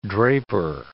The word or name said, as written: draper